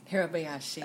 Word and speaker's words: Hirabayashi: Hirabayashi.